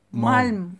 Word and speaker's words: Malm: Malm. Öyle mi okunuyor? – Bildiğin Malm'a bağlıyorsun.